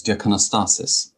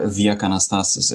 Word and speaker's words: Diakonostasis: So close, Diakonostasis. – Diakonostasis. – Oh, it's closer than most people get, so you get points for that. – Okay, thank you. Well, Nick, the audience can't read your mind, as you often say, so please tell us a little bit about yourself. – Yeah, so I'm Nick Diakonostasis, or Diakonostasis, if you want to pronounce it the Greek way.